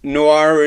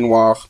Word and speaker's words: noir: I, I always think about film noir, like in the French way. – Yeah, I never say it like that.